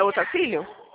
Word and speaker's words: Otacílio: Como que pronuncia? Tá certo? Seu nome é Otacílio? – Isso.